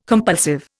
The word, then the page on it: compulsive: Indian